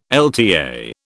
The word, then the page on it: LTA: British English